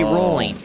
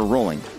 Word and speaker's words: Rowling: If it's British, it should be Rowling. – Okay, that's how I always pronounce her name. – It should be Rowling, not Rowling. Everyone keeps on saying that to me, because everyone I talk to who is British literally will say Rowling.